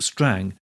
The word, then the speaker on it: Strange: Ferdinando's title was Lord Strang, spelt strange but pronounced Strang, so the troupe was known as Lord Strang's Men.